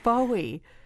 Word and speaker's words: Bowie: All you Americans out there, you know that we call him David Bowie. They insisted, our British counterparts in London, insisted that we pronounce it David Bowie, because that's how it's pronounced in Britain. And we kept saying, if we pronounce it Bowie, we will be laughed out of the country. It's Bowie.